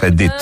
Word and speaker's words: Edith: Χαίρομαι που προφέρετε σωστά το όνομά της, Edith, και όχι Edith.